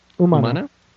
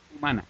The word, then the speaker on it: Humana: Bestia Humana? – Humana. – Humana.